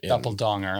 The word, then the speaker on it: Doppelganger: Doppeldonger, I believe is how it's pronounced.